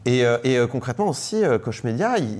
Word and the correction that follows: Core Media: enfin, ou Core Media, pardon, je prononce